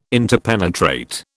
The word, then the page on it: interpenetrate: British English